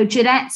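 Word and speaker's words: Jeannette: So Jeanette spelled with two N's.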